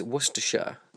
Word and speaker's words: Worcestershire: It is Worcestershire. That's how we say it, because that's where I live. I live in the county of Worcestershire, in England, Britain. And, yes, that is how it's said. Worcestershire. I mean, you can say Worcestershire if you want. We're not a shire. This isn't Lord of the Rings.